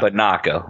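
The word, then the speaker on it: Binaca: Banaca, like binoculars.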